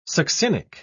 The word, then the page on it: succinic: British English